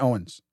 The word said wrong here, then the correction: Owings: Oh, Owings. Sorry. I keep saying that wrong.